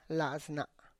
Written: laz na

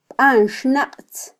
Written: banxnaqtz

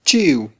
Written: chi'w

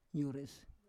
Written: yores

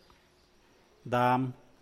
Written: daam

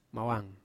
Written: mawang

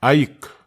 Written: aik

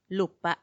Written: lupa

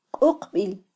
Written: quqbil

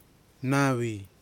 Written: nawi

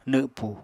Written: nwpu

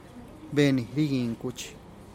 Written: ben riging kuch

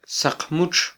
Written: saqmutx

